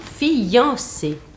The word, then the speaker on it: fiancé: Alors, il est évident que la prononciation correcte dans la conversation, dans la lecture, je n'envisage que cela pour le moment, hein. Prononciation et lecture, conversation et lecture, c'est fiancé, c'est-à-dire deux syllabes. Bon, il ne faut pas prononcer un i, mais on prononce un iode, fian, une seule syllabe, voyons.